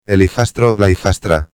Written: el hijastro/a

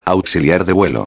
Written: auxiliar de vuelo

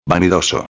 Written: vanidoso

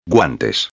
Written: guantes

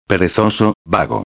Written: perezoso/vago

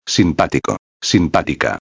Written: simpatico-a

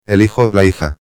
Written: el hijo/a

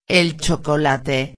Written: el chocolate

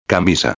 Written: camisa

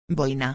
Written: boina

